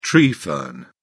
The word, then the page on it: tree fern: British English